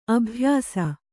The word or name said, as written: ಅಭ್ಯಾಸ